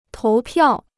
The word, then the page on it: 投票: tóu piào